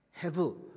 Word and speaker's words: hevel: Hevel. This Hebrew word pronounced hevel. Hevel, you know, hevel.